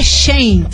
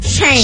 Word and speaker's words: Shein: Olha, pra mim que procurei no Google, é. já tô ligado nos tudos, é Shane. Shein. Shein. Porque vem do Shein.